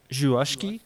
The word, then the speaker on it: Żuławski: This film called Possession, written and directed by Andrzej Żuławski. Is how the name is pronounced.